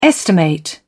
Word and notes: estimate: British English

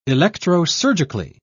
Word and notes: electrosurgically: British English

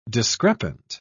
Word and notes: discrepant: British English

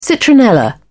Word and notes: citronella: British English

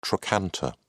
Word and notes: trochanter: British English

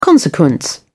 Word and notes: consequence: British English

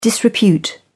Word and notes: disrepute: British English

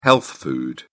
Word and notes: health food: British English

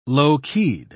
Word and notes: low-keyed: British English

